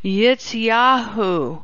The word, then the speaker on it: Jiziahu: On the Sabbath afterwards, this guy right here, his name is Jekahu Ben David. – Wallytron is having a hard time pronouncing the name. It is Yitzyahu Ben David. And it is spelled J-I-Z-I-A-H-U.